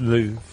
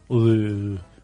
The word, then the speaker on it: Louvre: Louvre. – Is it? – Louvre. Mm. Louvre. You'd say it like that.